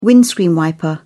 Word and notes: windscreen wiper: British English

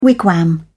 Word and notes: wigwam: British English

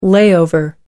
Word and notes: layover: American English

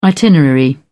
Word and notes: itinerary: British English

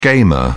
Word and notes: gamer: British English

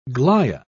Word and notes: glia: British English

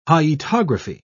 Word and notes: hyetography: British English

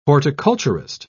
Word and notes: horticulturist: British English

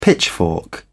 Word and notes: pitchfork: British English

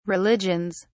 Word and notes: religions: British English

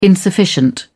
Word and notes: insufficient: British English

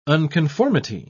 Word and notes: unconformity: British English

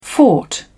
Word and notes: fort: British English

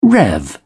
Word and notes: rev: British English

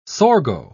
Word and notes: sorgo: British English

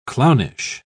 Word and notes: clownish: British English

